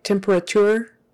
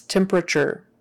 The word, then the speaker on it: temperature: That T is typically pronounced like a CH. If you were to pronounce it with a regular T, it would sound like temperature, but no one says it like that. It's temperature.